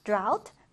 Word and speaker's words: drought: 자, 이 단어 발음 주의해 주세요. 아우 발음을 해주면 됩니다. 그래서 drought인데요. 자, 그래서 우리 예문 보면요. 가뭄은, 자, 이거 철자도 여러분 기억해 주셔야죠. drought causes water shortages.